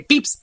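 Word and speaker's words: Pepys: yo le decía Pepis, pero hoy buscando me enteré que se dice Pips el apellido, Así que sigo Pepis es porque me, traiciona mi pronunciación mala anterior.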